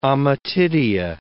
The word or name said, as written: ommatidia